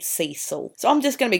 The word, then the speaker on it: Cecil: I do know that it's pronounced Cecil in English, in British English,